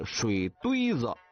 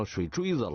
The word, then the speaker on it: 水堆子: 所以呢这个水堆子的发音也就变成了水锥子了。